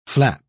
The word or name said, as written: flap